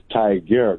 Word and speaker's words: Tigard: it almost sounds like tiger, but it's tiger. It's like T-I-G-A-R-D.